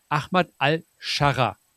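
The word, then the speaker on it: Ahmed al-Sharaa: Ahmad al-Sharra. Richtig oder falsch? – Ja, es ist richtig. Okay. Es ist schon richtig, also auf Arabisch ist es noch ein bisschen anders, aber das sind so Buchstaben, da verbringt man im Studium so einen Monat damit zu lernen, wie man die ausspricht.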